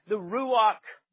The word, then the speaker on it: Ruach: The spirit of creation, if you're following along your outline, is called the Ruach Elohim. The Ruach Elohim, and Ruach is spelled R-U-A-C-K.